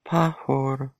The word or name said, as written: pahar